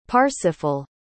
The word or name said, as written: parsifal